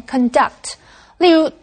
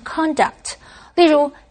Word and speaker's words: conduct: 所有的食宿都包括在套装行程中了。继续我们看的是 conduct 这个动词。D-U-C-T 这个字根有引起的意思。所以 conduct 是指组织、安排、实施或者是执行。注意它的重音是在第二个音节念成 conduct。例如 ,the experiments were conducted by professionals. 这个实验是由专业人士执行的。Conduct 也可以当名词，意思是行为。这个时候注意它的重音就在第一音节念成 conduct。例如 ,his conduct led people to believe he's a cruel person.